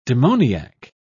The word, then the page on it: demoniac: British English